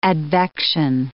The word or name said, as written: advection